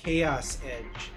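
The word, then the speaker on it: Kaoss Edge: and he's really into a fictional band called Chaos Edge. It's spelled K-A-O-S-S Edge.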